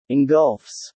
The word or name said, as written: engulfs